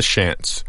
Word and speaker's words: Shants: They are called the Shants. One definite softened syllable, the Shant Isles, like a sea shanty, but with the Y trimmed away.